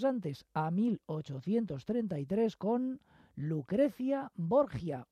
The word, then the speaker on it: Borgia: Pero claro, al pasarlo en italiano ellos dicen Lucrecia Borgia, para poder pronunciar esa J así de esa manera.